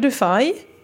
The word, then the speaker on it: Dufay: Utöver det har vi ju Jesper Papmel Dufay, som jag väljer att uttala så på det sättet, vilket är det korrekta, så det är inget konstigt.